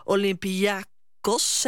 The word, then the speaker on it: Olympiakos: De Nijmegenaren hielden in Griekenland Olympiakos, zeg ik het goed? – Ja, je zegt het goed.